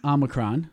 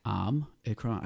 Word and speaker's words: Omicron: I said Omicron. – I don't know how to say it. Say it again. – Omicron. I had to Google this one because I thought it was Omicron. It is not.